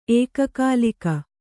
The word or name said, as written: ಏಕಕಾಲಿಕ